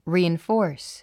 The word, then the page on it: reinforce: rìːinfɔ́ː r s; リーインフォース